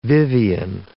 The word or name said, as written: Vivian